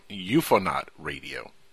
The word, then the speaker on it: UFONaut Radio: And if folks aren't familiar with his name, you might be familiar with UFO Naught Radio. – No, you said it wrong. UFONaut Radio. – UFONaut Radio. – UFONaut Radio. – UFONaut Radio. – It's like Ukraine.